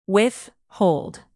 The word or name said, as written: withhold